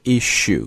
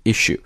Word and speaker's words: issue: 那么第三个呢就是在我们这里看到的就是 issue 表示的意思就是杂志的一期两期顺带讲一下这个单词的发音这个单词发音有两种啊，都是对的第一个是 issue 你发 sh 这个音啊 S 部分发 sh 这个音就是 issue 但是呢你还可以在这个 sh 前面加一个 S 音所以还可以读成 issue so it's sue rather than shoe so issue or issue both are fine